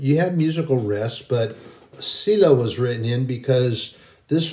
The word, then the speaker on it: Selah: Selah, S E L A H. The E is pronounced long, which means it's pronounced as an E, Selah.